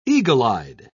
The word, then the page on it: eagle-eyed: British English